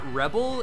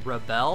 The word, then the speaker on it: Rebelle: Wait, so it's not Rebel, it's Rebel? It's B-E-L-L-E? – It's pronounced Rebel, yes. – So it's like R-E-B-E-L-L-E, like a woman, like Belle.